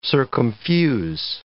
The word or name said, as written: circumfuse